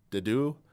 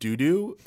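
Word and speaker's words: Dedue: For Blue Lions, I believe it's Dimitri, Ingrid, and Dudu. And he called him, said not doo-doo, which was pretty funny.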